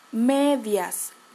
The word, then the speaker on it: medias: Other people uh, call it medias. Medias. We spell it M E D I A S. M E D IAS medias. And we smile the E or A a little bit.